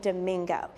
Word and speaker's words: Domingo: and now Domingo Hispanicized his name, adopting the Spanish pronunciation and became Domingo.